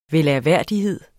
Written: -hed

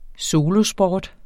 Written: solosport

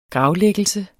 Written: gravlæggelse

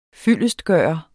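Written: FYLDESTGØRES

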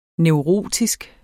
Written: neurotisk

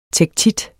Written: tektit

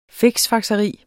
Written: fiksfakseri

